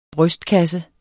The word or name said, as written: brystkasse